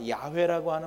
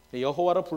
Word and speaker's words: 야훼: 근데 아마 야훼라고 하는 발음이 더 하나님의 이름에 가깝지 않겠느냐는 것이 요즘에 대략의 학자들의 의견입니다. 그러나 모르죠, 잘. 여호와로 부르셔도 괜찮고 하나님 다 이해하세요.